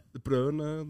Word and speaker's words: De Bruyne: og det, De Bruyne var plutselig Eller Browne, eller Hvordan mm. vil du uttale det? Eh. De Brøne det.